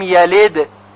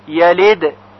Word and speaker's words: يلد: توجه داشته باشید که حرف دال در کلماتی مثل یلد و یولد ساکن هست و باید با قلقله تلفظ بشه، یعنی صدای اون جهشی داشته باشه که مستمع بشنوه اون را. لم یلد، یلد. پس اگر بگوییم یلد، یلد که صدای دال به خوبی شنیده نشه اشکال پیدا میشه.